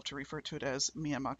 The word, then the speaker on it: Myanmar: It's spelled M Y A N M A R, which we here in America would pronounce Myanmar. Um, but in Burma, they actually drop pretty much all ending consonants. so it's it's Myanmar is how you'll hear them pronounce it.